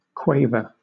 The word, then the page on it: quaver: British English